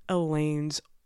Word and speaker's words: Eolane: Elaine's older sister. It is spelled E-O-L-A-N-E, and to the best of our understanding, it is pronounced Elaine.